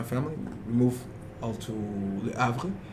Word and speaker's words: Le Havre: Le Havre. – Le Havre.